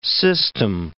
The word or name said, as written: system